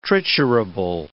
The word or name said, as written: triturable